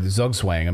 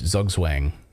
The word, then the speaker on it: Zugzwang: Well, it's a German word, Zugzwang. Americans would say it like English, Zugzwang.